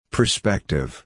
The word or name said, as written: prospective